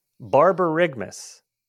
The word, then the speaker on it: borborygmus: borborygmus bar i'm i'm, I'm playing it that that's how google pronounces it b-o-r b-o-r-y